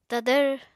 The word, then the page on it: tadvr: Apatani